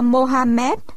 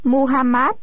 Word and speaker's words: Muhammad: Quá trình thành lập nhà nước Ả Rập gắn liền với quá trình thành lập đạo Hồi do Mohammed, còn đọc là Muhammad, truyền bá.